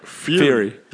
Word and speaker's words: Fury: How you pronounce his name? Fury or Furry? – Fury.